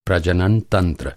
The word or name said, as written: प्रजनन